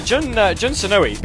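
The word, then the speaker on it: Jun Senoue: actually, Jun Sanoi, because I've actually found out through the SSMB that that's how you pronounce it. So, no more on me pronouncing it wrong.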